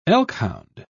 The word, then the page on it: elkhound: British English